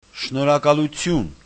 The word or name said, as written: shnorhakalutyun